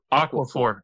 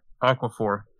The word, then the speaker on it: Aquaphor: Aquaphor. Aquaphor.